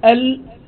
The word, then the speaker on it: ال: وهذه العبارة يعني كلمة ال أن تنطقها هكذا كأنها كلمة ال ولا تقول ألف ولا،